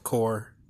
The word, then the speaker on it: Corps: That's ESUCORPS, spelled corps, pronounced core, like the Marine Corps.